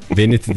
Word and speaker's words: Vanity: Vanity Fair diye bir dergi var ya. Güzel güzel giydirmiş minileri. – Vanity diye okunur da.